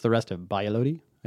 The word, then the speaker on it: Valladolid: Valladolid. Two double L's make a Y sound.